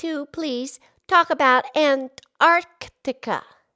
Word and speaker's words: Antarctica: Yeah, there's an extra C in there. Antarctica. Antarctica. – It's a little tricky, huh? – Yeah, it has the word arc.